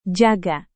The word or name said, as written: jaga